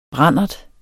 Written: brandert

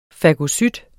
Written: fagocyt

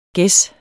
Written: ges

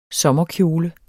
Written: sommerkjole

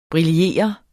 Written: briller

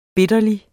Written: bitterlig

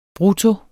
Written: brutto

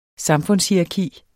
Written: samfundshierarki

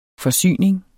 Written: forsyning

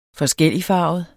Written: forskelligfarvet

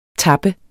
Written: tapper

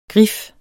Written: grif

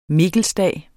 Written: mikkelsdag

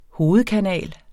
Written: hovedkanal

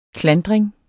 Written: klandring

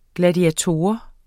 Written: gladiator

